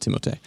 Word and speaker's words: Timothée: They asked Timothée Chalamet about this bucket, and that's how you say his name. His name is Timothée.